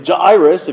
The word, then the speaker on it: Jairus: You can also pronounce his name really pretentiously, Jairus, if you'd like. That is the actual Greek, is Jairus. But I can't say Jairus all the time, so I say Jairus.